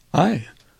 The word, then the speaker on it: Ai: So, would you pronounce the name of this curious little town so that I can get it straight? – Ai. – Yeah. Ai, ai.